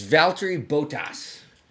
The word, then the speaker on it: Valtteri Bottas: It's Valtteri Bottas.